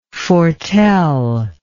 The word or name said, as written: foreteller